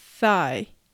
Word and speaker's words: thigh: thigh は th の発音の後に i と発音します。 i thigh thigh です。最後の gh はサイレントなので発音されません。